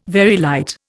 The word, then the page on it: Very light: Indian